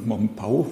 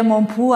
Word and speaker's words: Mompou: Ich denke mal, dass viele Leute den Namen vielleicht irgendwie mal gehört haben, Mompau oder wahrscheinlich auch noch Mompou aussprechen normalerweise. – Ja, es ist so, französisch wäre Mompou, aber ich glaube, Katalan ist dann Mompau.